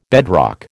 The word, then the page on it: bedrock: American English